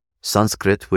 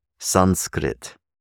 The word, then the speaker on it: Sanskrit: Sanskrit, which frequently in the West is pronounced as Sanskrit, or as even Sanskrit, those are incorrect pronunciations, by the way. Sanskrit, Sanskrit, Sanskrit.